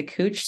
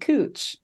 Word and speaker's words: Couch: That's literally how it's pronounced. Like the streetcar will announce like 10th and Cooch. And you're like, wow, what's the spelling? – Like couch, like it's the thing you sit on in your house.